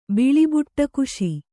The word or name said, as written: ಬಿಳಿಬುಟ್ಟಕುಶಿ